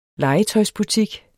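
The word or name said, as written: legetøjsbutik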